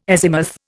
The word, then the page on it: azimuth: Indian